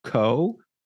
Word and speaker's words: !Xóõ: So it's in English pronounced co. But if you were to read it, like I'm looking at it on my screen right now, it's an exclamation point, an X, and then two O's, but each O has a different accent over the top.